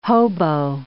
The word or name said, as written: hobo